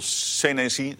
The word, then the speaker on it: Senesi: Ik bedoel, Senesi, zo moet je het uitspreken, collega's.